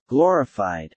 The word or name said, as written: glorified